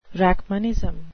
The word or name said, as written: rachmanism